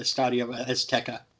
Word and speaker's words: Estadio Azteca: It was yet another uh, bad look for uh, Estadio Azteca. Did I say that right? – Yes. Okay.